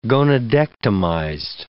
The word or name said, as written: gonadectomized